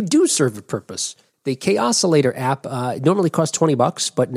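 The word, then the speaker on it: Kaossilator: But also, Korg is doing the same for their, and I'm going to say they pronounce this the K-Oscillator app. K-Oscillator app? – Ooh, yeah, sounds like chaos to me, yeah. – Oh, K-Oscillator, yes!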